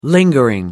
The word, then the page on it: lingering: American English